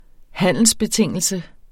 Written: handelsbetingelse

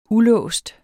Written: ulåst

